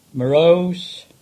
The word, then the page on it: Merrose: English